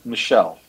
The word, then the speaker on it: Meshel: That's Meshel.